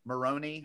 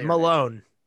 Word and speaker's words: Malone: I really like him, but his character and when his character got introduced, and then I think Jenna Malone, Maroney. – Yeah. – Is it Maroney? Malone? How do you say Malone. I think Malone. It's Jenna Malone.